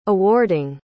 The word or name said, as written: awarding